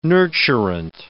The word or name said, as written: nurturant